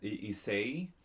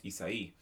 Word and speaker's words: Issei: On record, last time it was pronounced Issei, but it is correctly pronounced Issei. There's a accent on the last I when spelled out.